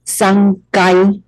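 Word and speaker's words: 三階: 那再来呢三楼呢三后面呢念成了嘎的读音哦，三该的读音部分，三该。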